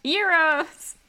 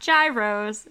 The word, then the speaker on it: gyros: Alpha, beta, gamma, euros. Which, if you ever go to Greece, they're not gyros, they're euros.